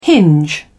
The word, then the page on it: hinge: British English